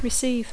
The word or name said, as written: receive